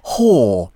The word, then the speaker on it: HOA: But in fact, it's a new record by the South Korean group, spelt H-O-A, pronounced, rather unfortunately, whore.